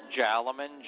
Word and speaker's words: Jaliman: Jaliman, J-A-L-I-M-A-N.